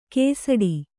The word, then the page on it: ಕೇಸಡಿ: kēsaḍi